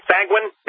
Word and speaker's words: sanguine: Does that sound right? Sanguine? Is that the word? – Yes.